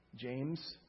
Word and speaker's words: James: In fact, there was this one guy, he couldn't pronounce my name, James, so he called me Jame.